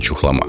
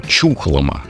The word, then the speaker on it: Чухлома: Кстати, я с удивлением узнал, что местные жители делают ударение на букву «У». То есть она э, чухлома, а не чухлома, как привыкли мы.